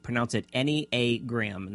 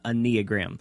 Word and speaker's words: Enneagram: If you're not familiar with how to pronounce it, Enneagram, and that's essentially what it is, Enneagram.